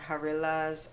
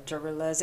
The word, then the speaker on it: Jarillas: called the Jarillas or Jarillas. It's spelled J-A-R-I-L-L-A-S.